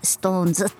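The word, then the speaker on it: SixTONES: はい、ようやく最近ねこのシックストーンズって言って書いてストーンズって読むのを、なんとなく記憶したばかりでございます。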